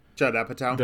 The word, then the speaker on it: Judd Apatow: Judd Apatow. Am I pronouncing it correctly? – Judd Apatow. – The who Yeah, Judd Apatow. Yes, thank you. I knew it sounded funny coming out of my mouth.